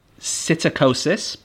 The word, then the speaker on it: psittacosis: So parrots can get something called psittacosis, but with a silent P at the start, and it's known as parrot fever.